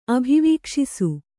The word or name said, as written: ಅಭಿವೀಕ್ಷಿಸು